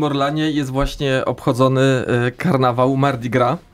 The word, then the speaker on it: Mardi Gras: tak, Mardi Gras, Mardi Gras.